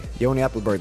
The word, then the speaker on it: Ioni Appelberg: Ioni Appelberg, that's perfect, Niko.